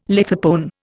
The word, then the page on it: lithopone: Indian